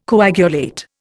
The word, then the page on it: coagulate: Indian